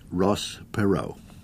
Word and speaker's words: Perot: H for Henry, but never spoken. H. Ross Perot, P-E-R-O-T, an approximate French pronunciation, for the good and proper reason that his ancestors were French settlers who went to Texas.